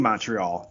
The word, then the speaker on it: Montreal: He tried to get us tickets down in DC because we were playing Montreal down in DC.